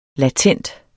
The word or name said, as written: latent